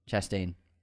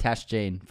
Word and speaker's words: Chastain: Chastain. – Chastain. – Chastain. We both said her name wrong. – Chastain. I said it correctly. – You said Tashjane first.